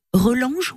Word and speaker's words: Relange: Et puis, à un endroit particulier, la Belle Roche de Relange, on prononce comme ça Elle se trouve à Relange, mais elle est également euh, nommée la pierre aux douze apôtres.